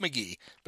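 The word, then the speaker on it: McGehee: MCGMC, capital G-E-H-E-E, right? – Yeah. It's just McGee, but it's spelled McGee.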